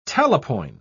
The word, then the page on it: talapoin: British English